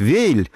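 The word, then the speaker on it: Weill: Кстати, заметьте, что я говорю Валь, хотя традиционное российское произношение предлагает Вейль. – Курт Вейль. – Курт Вейль. Но, вы знаете, альтернатива всегда во всех словарях также Валь. И я предпочитаю Валя в данном случае.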